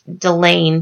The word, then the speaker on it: Delaine: And I believe it's pronounced Delaine, D E L A I N E Merino.